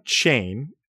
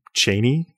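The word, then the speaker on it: Cheyne: So I was surprised to learn that John Chain, as we have come to pronounce his name in America, likely would have pronounced his name Chaney in Scotland, because I think that's just how his name is pronounced, actually.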